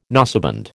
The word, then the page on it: noseband: American English